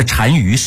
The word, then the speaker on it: 单于: 那单于是一个职位，这俩字是怎么写的呢？单就是单双的单，这是一个多音字，鱼是干钩鱼，这两个字在一起，在匈奴的职位叫做单于，就相当于汉人当时的大王或者是皇帝。